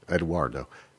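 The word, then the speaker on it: Edoardo: How do you pronounce Eduardo in Italian? Like that? – Sounds good. – Eduardo.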